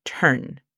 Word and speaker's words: turn: La pronunciación fonética de turn sería T-R-N. Observa cómo no damos lugar a vocales. Es T-R-N, turn. Signal sería S-I-G-N-O-L.